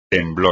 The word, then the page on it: temblor: espagnol